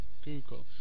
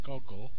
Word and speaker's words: Google: So it's long vowel sound. Ooh. Google. Yeah, Google. In Poland, my students always call it Goggle. Goggle, yes. But it's Google. So it's so important, the vowel sound on the stressed syllable.